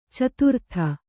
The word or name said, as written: ಚತುರ್ಥಿ